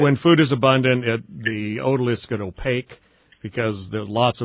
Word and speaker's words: otoliths: You say you cut open the otoliths, is that how you pronounce it? – Otoliths, that means ear stone.